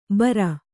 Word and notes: ಭಾರ: bhāra

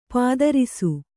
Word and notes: ಪದರಿಸು: kannada

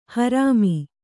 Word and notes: ಹರಾಮ: harāma

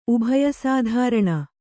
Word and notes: ಉಭಯಸಾಧಾರಣ: kannada

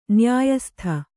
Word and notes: ನ್ಯಾಯಸ್ಥ: Kannada

